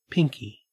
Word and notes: pinkie: Australian English

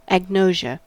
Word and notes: agnosia: American English

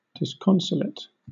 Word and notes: disconsolate: British English

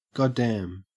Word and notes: goddamn: Australian English

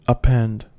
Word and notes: append: American English

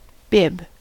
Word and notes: bib: American English

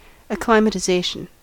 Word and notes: acclimatization: American English

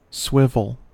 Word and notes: swivel: American English

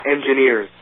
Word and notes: engineers: American English

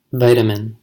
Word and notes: vitamin: American English

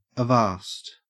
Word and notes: avast: Australian English

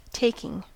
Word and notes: taking: American English

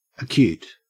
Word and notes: acute: Australian English

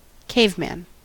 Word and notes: caveman: American English

